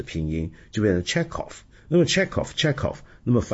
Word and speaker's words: Chekhov: 但其实中文这个翻译很准确，是契诃夫，这个喝呢其实是准确的掌握了原来俄文的发音，因为俄文里面契诃夫就是 Chekhov，那个 K 是不发音的，是不发音的，那个是个弱音，或者至少是 Chekhov，那是俄文的念法是比较准确的。